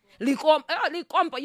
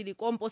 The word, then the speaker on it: Likompo: And you can Linkopo. You see, cannot even pronounce it well. Likompo. Tu wawumuza ati ndi Likompo, sati Linkopo. Likompo.